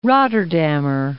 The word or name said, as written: Rotterdammer